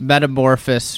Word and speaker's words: metamorphosis: and New Year's is gone undergone this metamorphosis for me. I don't. I think that's the right word. Morphosis. Morphosis. Metamor- you got to add a syllable Metamorphosis.